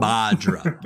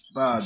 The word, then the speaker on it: Bhadra: Badra. The H is silent. It's Badra. Spelled B-H-A-D-R-A.